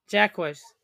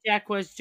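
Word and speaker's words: Jaquez: How did you say it the first? Jacquez. Okay, yeah, Jamie Jacquez Jr., yeah.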